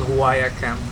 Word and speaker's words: Huaya Camp: It's called Huaya Camp. I hope I pronounced that correctly. – That's correct.